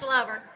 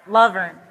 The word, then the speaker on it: Luverne: All right, Deanna Laverne. Did I say that right? No? – That's okay. – correct me. – It's Luverne. – Luverne. Okay. Got it.